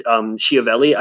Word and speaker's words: Schiavelli: Pigeon Man, as played by the great, late great character actor Vincent Schiavelli, I believe is how you pronounce his name,